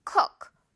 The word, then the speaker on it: cook: cook 우 짧게요. I'll cook you dinner. 어때요, 여러분? 오늘 발음 좀 은근히 까다로운데 어떤 포인트인지 알겠죠? 우 너무 길게 하지 말고 우 이렇게 짧게 한다.